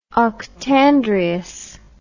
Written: octandrious

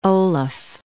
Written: Olaf

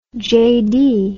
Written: JD

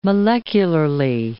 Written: molecularly

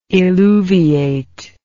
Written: illuviate

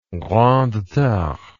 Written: grande terre

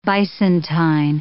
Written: bisontine

